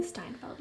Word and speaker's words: Steinfeld: Is that how you say her name? Steinfeld? – Hailee Steinfeld, yes. – Steinfeld.